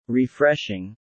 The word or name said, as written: Refreshing